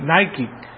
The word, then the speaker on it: Nike: Nike, porque de esa manera se pronuncia en inglés la i, Nike. Es Nike, vencedor, quiere decir, es palabra griega.